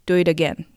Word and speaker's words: do it again: ちなみにこの do it again も I と A に挟まれた T があるので柔らかい D の発音になっています。 do it again ですね。